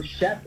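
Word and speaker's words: Shef: So Chef is spelled with an S.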